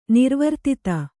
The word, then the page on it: ನಿರ್ವರ್ತಿತ: Kannada